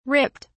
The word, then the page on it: ripped: British English